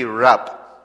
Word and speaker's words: Rabb: When you're praying, you say Rab. R-A-B-B, Rab, like emphasis on the B.